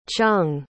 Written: chung

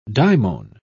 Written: daimon

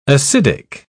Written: acidic

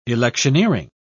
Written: electioneering

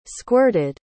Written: squirted

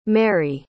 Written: mary

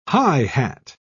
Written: high-hat

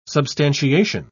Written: substantiation

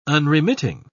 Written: unremitting

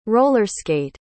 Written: roller-skate

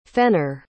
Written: fenner

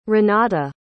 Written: renate

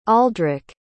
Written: aldrick

